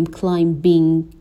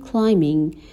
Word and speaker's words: climbing: ما نمیگیم climbing این بی تلفظ نمیشه میگیم climbing و